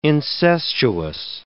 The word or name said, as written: incestuous